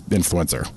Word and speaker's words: INFLCR: Nonetheless, their brand name is INFLCR, and their pronunciation is influencer, if you couldn't figure that out.